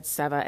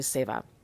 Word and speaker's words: seva: And I think I said seva and it's actually seva is the pronunciation of that word.